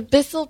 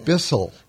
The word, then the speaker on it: Bissell: It's the Bissell, is that the pronunciation? – It's the Bissell Pet Foundation. – Bissell.